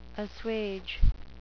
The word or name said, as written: assuage